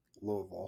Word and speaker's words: Louisville: You have to say it like you've got like gravel in the back of your throat. Louisville.